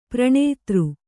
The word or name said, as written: ಪ್ರಣೇತೃ